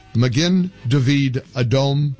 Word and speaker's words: Magen David Adom: And thank you for correcting my pronunciation, Magen David Adom, Israel's National Emergency Medical and Blood Services Organization.